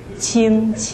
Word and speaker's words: Qing: Qing. – Q-I-N-G. – The last. Okay. – The other Q is pronounced like TS.